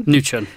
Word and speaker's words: Mnuchin: Was that right, Mnuchin? – Mnuchin. It's almost silent.